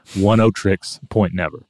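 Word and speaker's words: Oneohtrix Point Never: Oneotrix Point Never.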